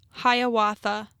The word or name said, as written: Hiawatha